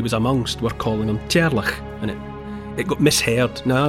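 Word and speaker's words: Teàrlach: It's a mishearing of the Gaelic form of Charles, which is I apologise unreservedly for my, what's going to be my mispronunciation, but in Gaelic speakers' mouths, Charles is rendered into a name that sounds a bit like Cherlach.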